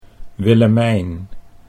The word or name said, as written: Willemijn